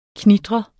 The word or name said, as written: knitre